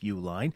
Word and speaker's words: Uihlein: That's spelled U-L-I-N-E. Uline is owned by an Illinois couple, Richard and Elizabeth Uline, in that case spelled U-I-H-L-E-I-N.